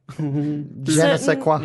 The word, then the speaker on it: je ne sais quoi: How do you say, je ne sais quoi? – Yeah, there it is.